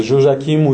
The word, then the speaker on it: Joachim: C'est Joachim ou Yehoyakim. Voilà. Suivant comment on écrit en français ou dans notre langue, le nom hébraïque, en fait. C'est un petit peu comment on considère qu'on doit le prononcer.